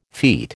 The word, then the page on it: feat: American English